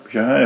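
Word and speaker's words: Bejar: Bijar, I pronounced that right.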